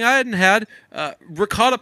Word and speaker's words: ricotta: Isn't it regatha? What? That's how I pronounce it. Regatha cheese? – Ricotta.